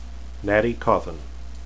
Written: natti

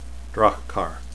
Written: draghkar

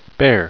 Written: bayr

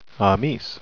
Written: amys

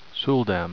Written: suldam

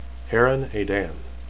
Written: heran

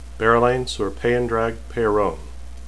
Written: berelain

